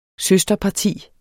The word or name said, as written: søsterparti